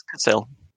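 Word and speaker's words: Cassel: And so that it was a good thing that, one of our newest characters, played by Vincent Castle, or Cassell, or however you say his name, but it's Cassell.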